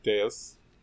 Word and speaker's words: Devs: Written by Alex Garland, who just finished Devs, Annihilation, Ex Machina. This is one of his... – You're pronouncing it wrong. – Yeah, Deus.